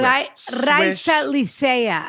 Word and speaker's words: Raiza Licea: Raiza Licea, I believe.